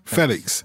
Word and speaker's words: Félix: you're giving us pronunciation lessons, it is still Felix, is it? Because I heard you kind of do a Felix kind of Felix. Thing. – Felix. Felix. Yeah. – So not only do we have to get our heads around Joao, but as well Felix as well. Yeah, there's the little accent on the E.